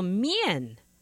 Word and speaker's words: Mian: Okay, so Mian is the northern pronunciation. And of course, there are variations. When the Mian went to the south, all of a sudden it became Mean.